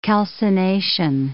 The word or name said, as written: calcination